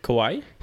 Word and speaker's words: kawaii: But anyway, so she would always wear like those fucking, I think you call it kawaii beanies. How do you say it? – Kawaii? – Kawaii, there you go, the one beanies.